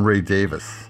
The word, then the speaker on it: Davies: That's what we call him. In England, they call him Ray Davis. I don't know why. It's just the way they pronounce it. Like Bachman and Bachman. Davies and Davis.